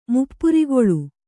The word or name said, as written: ಮುಪ್ಪುರಿಗೊಳ್ಳು